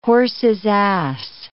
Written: horse's ass

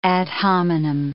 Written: ad hominem

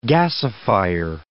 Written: gasifier

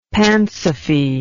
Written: pansophy

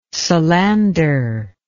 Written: solander

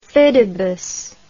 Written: fidibus

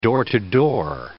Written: door-to-door

